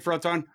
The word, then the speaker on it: Froton: CF, did I say that right, Froton? – Correct.